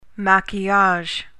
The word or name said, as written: maquillage